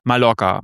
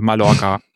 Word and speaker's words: Mallorca: Leute, alle spanischen Namen wie Mallorca wird das Doppel-L als J ausgesprochen und nicht als Mallorca. Also Mallorca.